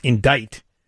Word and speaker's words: indict: Though the word is pronounced indict, it has a C in it.